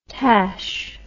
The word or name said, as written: tache